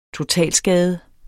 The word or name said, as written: totalskade